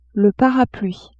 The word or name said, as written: parapluie